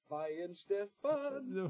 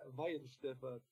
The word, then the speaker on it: Weihenstephan: Oh yeah, Weihenstephan. – Weihenstephan. All right. – Yeah, Weihenstephan.